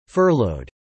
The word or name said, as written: furloughed